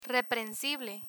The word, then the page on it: reprensible: spanish